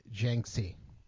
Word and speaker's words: Jiangxi: In two counties or provinces, which I cannot pronounce, Yunhong and Yaxi province, is encouraging. I know I pronounced those wrong. I'm sorry. Don't come for me. – Yihuang and Jiangxi.